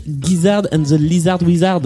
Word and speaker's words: Gizzard and the Lizard Wizard: Et donc tu aimes euh, ce groupe qui s'appelle King Gizzard and the Lizard Wizard. Exactement, tu l'as très bien prononcé.